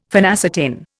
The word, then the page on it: phenacetin: Indian